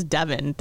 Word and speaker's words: Devon: She goes, you know, technically it's Devon. That's how they pronounce in England.